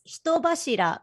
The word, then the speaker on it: Hitobashira: It's Hitobashira, but like there's no need to pronounce it super Japanese. – Okay. – Yeah, right. – There's no need to like do the hard R.